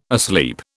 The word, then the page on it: asleep: British English